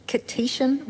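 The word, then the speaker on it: Kittitian: So he's a British Kitsian writer. Is that how you say it? – From St. Kitts, yes.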